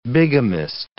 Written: bigamist

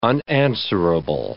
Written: unanswerable

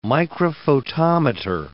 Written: microphotometer

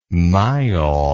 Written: myall

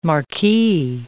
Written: marquee

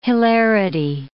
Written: hilarity

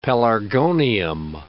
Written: pelargonium

